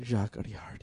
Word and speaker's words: Jacques Audiard: Jacques Audiard, yes.